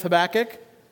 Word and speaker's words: Habakkuk: You can put the emphasis on a different syllable, but we're going to go with Habakkuk.